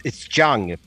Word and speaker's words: Tseung: Well, it's Tseung, but it's fine with Tseung. It doesn't matter to me because I'm not really 100% sure how to say it myself.